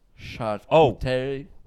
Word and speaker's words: charcuterie: It's not a hard C. It's a charcuterie.